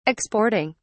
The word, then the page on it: exporting: British English